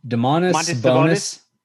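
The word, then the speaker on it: Domantas Sabonis: DeMontis Sabonis. Did I say that right? – Yes.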